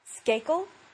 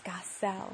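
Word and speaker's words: Skacel: I said skakel, you know, skakel knitting needles, and the lady was like, no, it's skasel.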